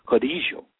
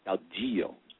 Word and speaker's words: caudillo: And some Spanish speakers in some different countries might say it caudillo, but it's most commonly pronounced caudillo, and the D in Spanish is sort of a, it can be a z, it can be a th, it just depends on what country you're in.